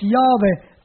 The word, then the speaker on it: Jahwe: dass dieses Tetragramm, was ja im Hebräischen nur als Konsonanten geschrieben wird, nämlich ein J, ein He, ein Waf und ein He, dass das als Jahwe ausgesprochen werden muss.